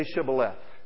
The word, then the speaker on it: shibboleth: "Okay, well, say Shibboleth." See, the Ephraimites can't pronounce that H.